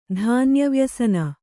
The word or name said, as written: ಧಾನ್ಯವ್ಯಸನ